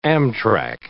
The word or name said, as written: amtrac